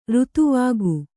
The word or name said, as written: ಋತುವಾಗು